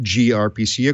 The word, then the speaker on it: gRPC: We have gRPC, which is pronounced gRPC according to their own style guide.